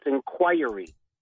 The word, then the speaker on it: inquiry: We're all saying the word wrong. It's inquiry. Inquiry. I learned that at broadcasting school. That was the first word they threw out there that people mispronounce, and Caribbean. – Oh, okay. Caribbean. Well, thank you. That I can always learn something. Thank you very much. Inquiry. – Inquiry. – I had the emphasis on the wrong syllable or something.